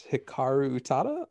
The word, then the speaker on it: Hikaru Utada: I'm going to butcher it, but it's Hikaru Utada, I believe. So that's my pick for video games. – No worries in the pronunciation.